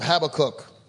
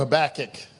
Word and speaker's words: Habakkuk: Turning your Bibles to the book of Habakkuk or Habakkuk. – How do you say that now? – Who took the cook? – Oh.